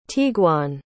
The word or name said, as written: tiguan